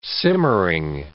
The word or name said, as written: simmering